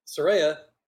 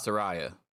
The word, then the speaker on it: Saraya: Now you're bringing in Saraya, which is her correct pronunciation, by the way, according to her argument on Twitter. Saraya. – So it's not what Excalibur was yelling out. It's not Saraya. – Right. So, okay. Yeah, she said on Twitter, it's Saraya. She spelled it out phonetically.